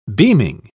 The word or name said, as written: beaming